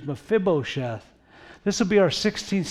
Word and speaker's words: Mephibosheth: His name is Mephibosheth. How about that for a name? Mephibosheth. I wrote it out for you. Let's see if we can pronounce it. Mephibosheth.